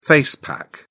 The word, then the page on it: face pack: British English